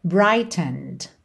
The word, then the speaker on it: brightened: E essa próxima linha aqui ficou muito interessante essa palavra brightened, brightened. Brighten é o verbo, certo? Ele aqui está no passado, mas esse é da terminação ed não é pronunciado.